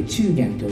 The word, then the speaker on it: 中間: で、中元というのはあの中間と書きます。中間と書いて中元と読むんですけども、これはあの侍の中でもかなり下層の身分に位置するわけなんです。